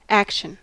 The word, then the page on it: action: American English